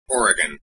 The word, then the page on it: Oregon: American English